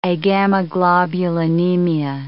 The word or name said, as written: agammaglobulinemia